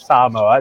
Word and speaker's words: Samoa: That's how you pronounce it, Samoa. It's very hard for people here on the mainland to pronounce it for some reason. But the American Samoans are okay with the fact we've been mispronouncing it for literally 100 years.